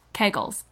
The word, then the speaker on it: Kegels: I'm not saying that anyone's ever gotten injured using their Kegels, but it is Kegels. Am I saying it wrong again? – Yeah, you're saying it right.